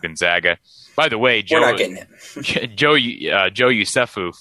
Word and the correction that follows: Yesufu: from or am I pronouncing that right? Yesufu. There you go.